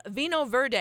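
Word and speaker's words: Vinho Verde: Number two of the three is a Vino Verde.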